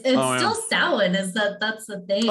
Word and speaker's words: Samhain: So Samhain, which if you're looking at the word itself, looks like it should be Samhain. – Oh, that's not how it's pronounced.